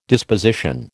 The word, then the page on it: disposition: American English